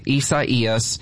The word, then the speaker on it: Isaias: Yeah, you're going to have to help me get used to saying that because it's spelled I-S-A-I-A-S. – That's right, but it's pronounced Isaias. That's the official pronunciation from the Hurricane Center, but and they do the naming.